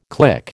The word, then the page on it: click: American English